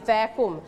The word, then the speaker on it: vacuum: Vacuum. ตัว V นะคะจะมีเสียง F เหมือนเสียงฟอฟันเล็กน้อยเขาเรียกออกเสียงว่า Vacuum the floor.